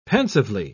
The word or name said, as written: pensively